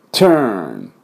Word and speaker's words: turn: このプシュッと音です。 t t t turn、 turn、こんな感じですね。